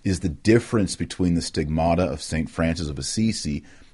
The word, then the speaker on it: stigmata: That's, you actually pronounce it stigmata in the Greek.